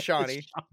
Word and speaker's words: Shawnee: How do you say Shawnee? – Her name? – Shawnee. – Is it Shawnee? – It's Shawnee.